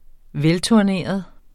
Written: velturneret

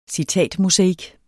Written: citatmosaik

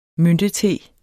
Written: myntete

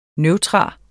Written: neutrum

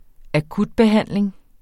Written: akutbehandling

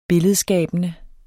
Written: billedskabende

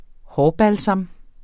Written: hårbalsam

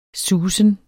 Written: susen

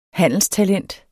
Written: handelstalent

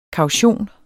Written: KAUTIONENS

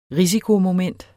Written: risikomoment

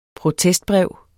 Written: protestbrev

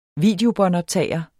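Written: videobåndoptageren

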